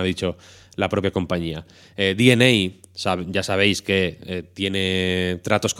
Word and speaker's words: DeNA: Primero, tenemos la de Nintendo y Dena, DNA, perdón, pronunciemos con propiedad,